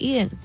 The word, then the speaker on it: Eaton: Well, that's how you say it, Eaton.